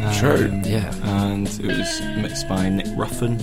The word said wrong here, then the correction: Rowan: who's been Nick Rowan. Nick Rowan. It's Rowan. Oh, I've been pronouncing that wrong for a long time.